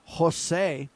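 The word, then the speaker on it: José: And then they run around and they promote Jose, pronounced with an H for the J designator, right?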